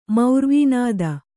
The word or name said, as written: ಮೌರ್ವೀನಾದ